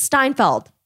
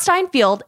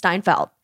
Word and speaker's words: Steinfeld: I was like, can you just, it's Steinfeld. It's not Steinfeld, it's Steinfeld. – I don't know that I knew that. Did I know that? – I think I call her Seinfeld, Hailee Steinfeld.